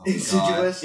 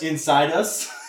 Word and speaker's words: insidious: Youngstown, Ohio does not know how to say the word insidious, or at least read it, because insidious. – Oh, insidious. – God. Inside us.